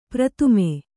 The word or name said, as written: ಪ್ರಥಮೆ